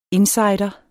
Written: INSIDER

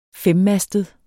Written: femmastet

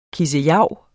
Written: kissejav

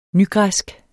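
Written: nygræsk

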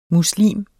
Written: muslim

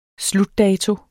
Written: SLUTDATO

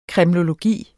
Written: kremlologi

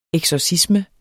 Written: eksorcisme

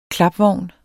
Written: KLAPVOGNE

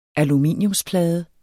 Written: aluminiumsplade

